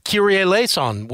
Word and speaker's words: Kyrie Eleison: And one of the songs is an old Mr. Top 40 hit that Kyrie Eleison, which is At least I think I pronounced it right.